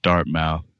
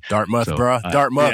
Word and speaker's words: Dartmouth: Those included Stanford, Harvard, Columbia, Dartmouth. I'm probably saying that incorrectly. – Dartmouth. – Yeah, I apologize to the alumni of Dartmouth, just to name a few.